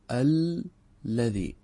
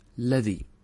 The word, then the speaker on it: الذي: أنت تلاحظ لو تدقق في كلمة الذي، لو تنطقها نحن ماذا نقول؟ الذي الذي. فالظاهر في النطق أنها لامان، مع ذلك نحن كم لام نكتب؟ نكتب لاما واحدة، فنكتبها بلام واحدة، مع أننا ننطقها بلامين: الذي.